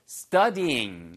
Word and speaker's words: studying: study가 이미 이 발음으로 끝나기 때문에 studying이 아니고요. study, 그다음에 ing이 하나 더 붙여요. 그래서 studying. 이렇게 이 발음이 두번 연속되는 것처럼 발음하셔야 알아듣습니다. 원어민이요.